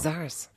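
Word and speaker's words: Czars: Du bist nach New York gezogen, du hast Musik gemacht, du hattest eine Band, die Charles? – Die Sars. – Ja. – Spricht man so aus, als ob da kein C wäre. – Sars.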